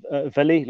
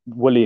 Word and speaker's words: Willie: So it's pronounced Villy, like Villa, Aston Villa. So the W is essentially pronounced as a V. But yeah, so it's Willy Explore, I guess, is the other way to sort of call it.